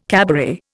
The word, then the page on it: cabaret: Indian